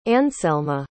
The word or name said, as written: anselma